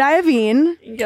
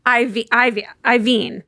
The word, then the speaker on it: Iovine: Because he'd say Iovine. Mm-hmm. And we say Iovine. And one's two syllables and one's three.